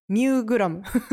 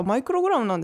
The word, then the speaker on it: マイクログラム: ミューグラムミューって読めてたからいやマイクログラムなんですね、